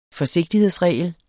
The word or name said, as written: FORSIGTIGHEDSREGLER